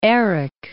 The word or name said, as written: Erik